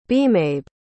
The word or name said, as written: bemabe